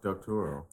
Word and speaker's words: Doctorow: Why do you keep saying it that way? It's Doctorow. No, it's not. He's not a Marvel character. Doc- Doctorow.